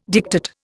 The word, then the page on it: diktat: Indian